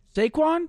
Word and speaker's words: Saquon: Saquon? – Saquon these nuts.